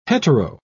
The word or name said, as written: hetero